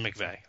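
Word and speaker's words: McVey: So anyways, but it's about a guy named – it looks like it's pronounced Tim McVeigh, okay? – Mm-hmm.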